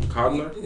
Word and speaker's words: Coogler: Coogler? – Yeah, Coogler.